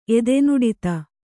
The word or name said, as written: ಎದೆನುಡಿತ